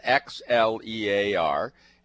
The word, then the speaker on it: Xlear: And so it's clear nasal spray, and it's spelled X-L-E-A-R. And the X is pronounced like a C.